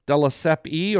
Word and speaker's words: Della Siepe: Della Seppi, or is it just Della Sepp? – Della Seppi.